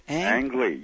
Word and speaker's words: Ang Lee: Is that Ang Lee? Is that how you pronounce that? – Ang Lee, yes.